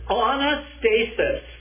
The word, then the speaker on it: anastasis: 34, and as for the fact that he raised him from the dead, and this is that second word, the word is anastasis. Anastasis. A-N-A-S-T-A-S, long A-S. Anastasis. Anastasis. Long A-S-I-S. Anastasis.